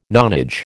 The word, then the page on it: nonage: American English